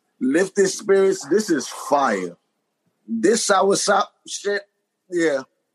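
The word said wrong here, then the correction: soursop: Say it right. Sour shot. – Sour sop. This sour sop.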